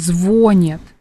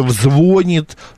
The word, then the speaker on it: звонит: И поэтому в любом случае работники СМИ, журналисты будут говорить так, как говорили всегда, и вот это «звонит», но это не по-русски совершенно.